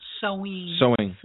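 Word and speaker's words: Samhain: It's pronounced snowing. – Mm. It's snowing? – Sewing.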